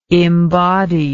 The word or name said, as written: imbody